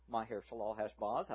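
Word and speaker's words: Maher-shalal-hash-baz: And then the Lord said to me, name him Maher Shalal Hashbaz. Many people struggle with this name, but that is the correct pronunciation. Maher Shalal Hashbaz, I have shared with you before that I wanted to name my son Maher Shalal Hashbaz, and God gave me girls.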